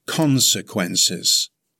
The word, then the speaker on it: consequences: It has four syllables. Stress the first syllable. It's pronounced consequences. Consequences.